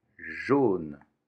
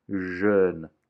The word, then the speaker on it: jaune: Amarelo, jaune, jaune. É importante insistir sobre o som O de jaune e não pronunciar jeune, que significaria jovem, jaune, amarelo, jeune, jovem.